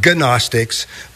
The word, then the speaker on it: Gnostics: They were called Gnostics, but it's spelled G-N-O-S-T-I-C-S. It's not pronounced Gnostics, but it's Gnostics, okay?